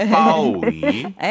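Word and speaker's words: 빠오위 (暴雨): 그 사성 삼성이고요. 발음은 빠오위입니다. – 네. 이제 선생님 하셔도 될 것 같아요. – 빠오위.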